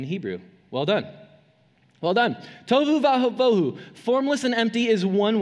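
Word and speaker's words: tohu vavohu: Um, and so it's, it, this is actually a really neat phrase in Hebrew, um, and it's, it rhymes. It's, it's the phrase for uh, formless and empty, tovu vavohu. Tovu vavohu. Can you say it with me now? Tovu vavohu.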